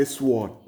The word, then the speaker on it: sword: Ebude, they don't say sword, they say sword. They spell it S-W-O-R-D. They spell it like sword, but they pronounce it sword. So the W is almost silent, sword.